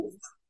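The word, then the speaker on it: Fiennes: And she was in Dust with, okay, here I don't know how to say his last name, with Joseph Finas, because my brain wants to say Finas.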